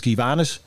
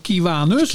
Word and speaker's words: Kiwanis: Is het nou Kiwanis, Kiwanis? Kiwanis. Kiwanis. En het is, ja, je kan het uitspreken. En uh, waarschijnlijk een Amerikaan spreekt het anders dan wij uit.